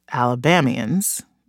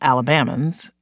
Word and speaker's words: Alabamians: First of all, it's Alabamians, not Alabamans, and then it's Alabamians and not Alabamians.